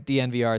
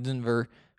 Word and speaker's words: DNVR: That is how you say it, by the way, DNVR. It's not like Denver, because there are no E's in it. If you see it, it's DNVR.